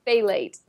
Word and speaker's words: phthalate: uh, it's ph- Am I saying that right? No, I You're don't close. think I am is phthalate. Phthalate.